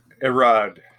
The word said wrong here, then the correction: Arad: Avi, how do you pronounce his name? – I believe it's Avi Arad.